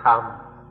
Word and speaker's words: Dhamma: The Thais just pronounce it in their own way, Dhamma or Tham, but still have to use this old Indian word.